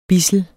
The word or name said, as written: BIDSLET